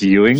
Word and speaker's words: Ewing: It's Ewing? – Ewing. – It sounds like you're really disgusted with me, like, ew. So no, think of like the basketball player or the guy from Dallas.